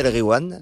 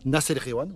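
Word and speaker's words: Nass El Ghiwane: Et quand il y a GH, tu dis re. Tu fais comme si c'était un R. – D'accord. – Nas El Gwiam, voilà.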